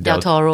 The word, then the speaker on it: Del Toro: Del Toro.